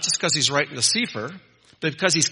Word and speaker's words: sefer: If you pronounce this word sefer, it means a book.